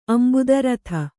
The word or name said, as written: ಅಂಬುದರಥ